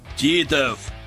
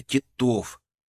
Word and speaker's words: Титов: Ударение на второй слог, сказал я. Титов!